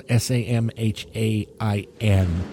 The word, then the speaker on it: Samhain: Halloween is actually based on an ancient Celtic holiday called Samhain, although it's spelled S A M H A I N. Leave it to the Celtic to make Samhain pronounced Samhain.